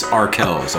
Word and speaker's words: Arkells: And it's spelled A-R-K-E-L-L-S. It's not like the shortcut for R. Kelly. It's R. Kells, okay? – Oh my God, he touched me once.